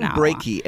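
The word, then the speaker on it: Breakey: I believe it's Brakey. His first name is Norman, so maybe we'll just call him Norman. – Norman Brakey, as in heartbreaky.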